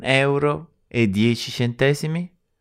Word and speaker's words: euro: Now, the pronunciation of euro is a bit tricky. It took me ages to get it somewhere decently similar to how Italians say it. The secret is to separate out the E and the U at the beginning.